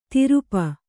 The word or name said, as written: ತಿರುಪ